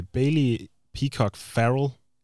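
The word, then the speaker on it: Bailey Peacock-Farrell: Lad os gå ned i den anden ende og snakke øh, Bailey, Peacock, Farrell. Det var rigtig udtale, ikke? – Jo. – jo. – Ikke ligesom rapperen.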